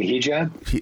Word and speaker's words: hijab: And there was this young woman with a little red Target shirt on, but with a Habib. And that's how you pronounce it, right, Stephen? – I think you mean hijab. – That's not how you pronounce it at all, Glenn. It's called a hijab.